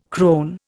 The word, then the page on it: crone: Indian